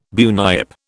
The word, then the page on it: bunyip: British English